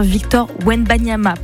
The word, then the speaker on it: Wembanyama: Bien ce basketteur Victor Wembanyama, pardon pour l'erreur de prononciation, je préfère corriger.